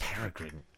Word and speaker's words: peregrine: It's pronounced Peregrine. – Okay, Peregrine. – I'm pronouncing it wrong. – It's like paraffin. – It's no, paraffin Peregrine. Is a different other word. – Peregrine. – Peregrine, Peregrine means wanderer or foreigner.